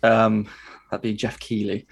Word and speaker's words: Keighley: Um, mm-hmm. Keithley. Yeah, I mean, it's, look, pronounced, been... it's spelt Keithley, like Keithley Market up north. I will refer to him as Keithley. Jeff Keithley. – It's Keighley.